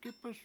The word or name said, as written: gIp1s